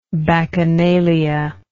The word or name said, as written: bacchanalianism